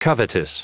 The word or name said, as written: covetous